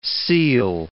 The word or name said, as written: seel